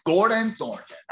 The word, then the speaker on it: Gordon Thornton: Gordon Thornton. I like that. Strong. – You got to have the emphasis, man.